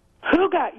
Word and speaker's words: hygge: You are one of the first people to say that right in an interview. We have had everyone say things from hygge to hygge. It's a funny word to say. It's very Danish. But yeah, it's hygge, like you're doing an enthusiastic clearing of your throat.